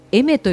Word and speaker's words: Aimer: もうおばちゃんね、 2人に教えてもらって初めてあの歌手の読み方がわかりましたあの AIMER と書いてエメと呼びます。